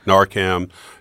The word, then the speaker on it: Narcan: Narcan. – I never say it correctly. Can you pronounce it for me? Narcan.